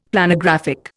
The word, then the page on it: Planographic: Indian